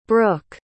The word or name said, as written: brooke